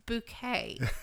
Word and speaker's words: Bucket: And she is so controlled by ego that she demands that her last name, which is Bucket, B-U-C-K-E-T, Bucket, be pronounced Bouquet.